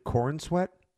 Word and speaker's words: Corenswet: Yeah, is that how do you say his name? David Corenswet? – Yep, I believe that's right.